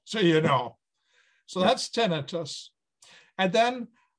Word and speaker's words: tinnitus: And I also have tinnitus, which you can pronounce as tinnitus or tinnitus. Either way is correct. I say tinnitus, which is a ringing, roaring, humming, hissing, buzzing sound in your ears.